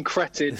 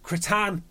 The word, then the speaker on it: Cretin: Goujon and Cretin. – yeah. – Great names. – Cretan, I think is how you say it. It's like bouquet and bucket.